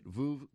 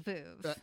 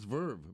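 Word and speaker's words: Veuve: Now I'm going to say it right. Vuv Clicquot. – Vuv. – Vuv. – It rhymes with love. – But everybody says Vuv. – Actually, the Google says Verve. Verve Clicquot. – Yeah, but Google is wrong.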